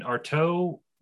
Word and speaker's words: Artaud: And Arto was, it looks, his name looks like it's spelled Artaud, but like A-R-T-A-U-D.